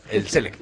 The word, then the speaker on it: Select: No, es el cine como selecto, para que lo selecto, pero en inglés. – El Select. – Sí, exactamente. – Ah, no estaba pronunciando la T.